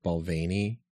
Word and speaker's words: Balvenie: That it's pronounced Balvenie, and I've been saying it wrong this whole time.